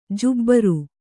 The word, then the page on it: ಜುಬ್ಬರ: jubbara